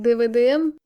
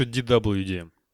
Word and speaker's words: DWDM: А как правильно говорится, ну вот, как ты там говорил, DVDM? А почему, я слышала, что DVDM, там, SVDM, то есть не V, не как-то так произносится, нет? – Не, но это скорее V, мне кажется, ну там W вообще, это может быть, это я неправильно произношу, просто мне прям по уху так сильно режет это. – Я обычно слышу DWDM. – Во, Марат прям расово верно говорит.